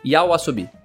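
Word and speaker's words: YOASOBI: A opening, que é Idol, o nome, que é do Aosobi, né? Yaosobi, assim que pronuncia, Yaosobi.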